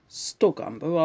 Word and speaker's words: Stogumber: And I've always wanted to get off or on at Stogumber, because not many places are called Stogumber, are they? It sort of rhymes with cucumber, but not quite, you know.